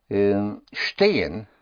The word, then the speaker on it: stehen: Stehen, which is spelled S-T-E-H-E-N, because in German, S-T always has the sound of an S-H-T.